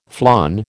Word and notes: flan: American English

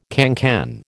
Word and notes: cancan: American English

